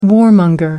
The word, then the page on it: warmonger: American English